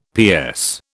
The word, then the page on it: PS: British English